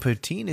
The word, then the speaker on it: poutine: Saying poutine is not.